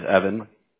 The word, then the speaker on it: Evan: My name is Evan. – How do you spell that? – E-V-A-N. My last name is Diaz, D-I-A-Z.